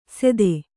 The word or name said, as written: ಸೆತ್ತೆ